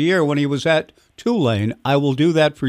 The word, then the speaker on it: Tulane: He said, "Well, first of all, it's pronounced Tulane," and conversation went on from there.